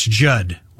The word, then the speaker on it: Judd: That's Judd. With two D's, the second D is silent.